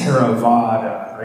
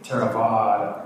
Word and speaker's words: Theravada: You know, like on this book, you know, the first word is Theravada, right, with a long A. Theravada. There's like a bar over the A.